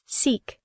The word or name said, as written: seek